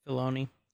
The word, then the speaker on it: Filoni: Filoni.